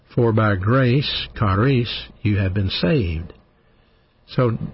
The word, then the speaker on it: charis: But the Greek word for grace in the New Testament, we spell it G-R-A-C-E, actually is spelled C-H-A-R-I-S, charis. Charis, that's the word grace.